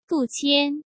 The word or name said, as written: Du Qian